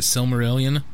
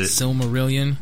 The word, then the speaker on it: Silmarillion: I read somewhere recently that they are trying to get the rights from Tolkien's estate to make, I always butcher it, Silmarillion. – That's it. – Silmarillion. – Yep. – Yeah. – That's it. – It's the middle L messes me up.